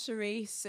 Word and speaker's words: Cherise: My name is Cherise. Oh my God. That was so obnoxious. My name's Cherise. It's spelled like Cherie with an S.